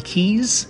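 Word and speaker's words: Quays: The Airbnb was located in a place called the Keys, which is spelled Q-U-A-Y. And key means, in this case, means platform near the water.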